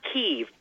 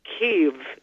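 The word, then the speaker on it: Kyiv: It's the first I. It's not Kiev. People think it's just Kiev now, but it's actually still two syllables. And the first I is sort of like you say it in kids. So it's more like Kiev, Kiev. It's like a slight multi-syllable there.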